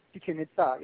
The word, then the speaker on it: Chichen Itza: Okay, the same person, Sharon, wants to know, any work at, I believe, I'm not sure how to pronounce this, is it Chichen Itza? – Chichen Itza. Yeah, I was at Chichen Itza at the sacred well again in the 70s.